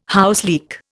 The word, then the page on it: houseleek: Indian